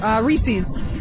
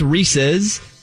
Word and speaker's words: Reese's: And also, do you say, because it's Reese's, but a lot of people say Reese's. But how do you say it, Amy? – Reese's.